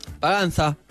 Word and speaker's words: Paganza: Les recomiendo que se bajen la app, la tienen en cualquier tienda, Paganza con Z, como se pronuncia acá. – Paganza.